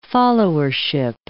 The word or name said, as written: followership